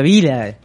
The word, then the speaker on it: Villa: O sea, el apellido de mi marido, si se, si se escribe Villa, se pronuncia Vila.